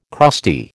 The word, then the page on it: crusty: American English